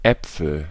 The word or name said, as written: Äpfel